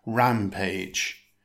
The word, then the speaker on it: rampage: It consists of two syllables. Stress the first syllable. It's pronounced rampage.